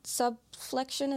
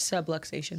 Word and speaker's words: subluxation: That was an injury she dealt with, and she had a similar incident where it wasn't a complete subflexion. Is that how you say it? – Subluxation.